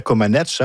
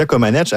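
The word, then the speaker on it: Comăneci: On parle d'un spectacle de variété auquel assistera bien sûr Nadia Comaneci. Hein. On a appris cette semaine qu'on ne doit plus prononcer le I.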